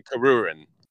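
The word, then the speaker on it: Kuririn: It's Krillin, and then the Krillin. Japanese would be Karurin.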